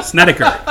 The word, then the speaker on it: Snedeker: Brandt Snedeker. – Snedeker. – Snedeker.